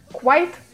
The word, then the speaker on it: quite: Произносится оно как quite, и здесь очень четко можно услышать в середине слова звук w.